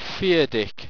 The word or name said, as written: pheardic